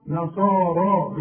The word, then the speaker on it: نصارى: وبرضو الياء دي بيقول لك حولها لي إلى ألف، ورسم لي ألف فوق الياء، تكتب هكذا وتنطق نصارى بالألف.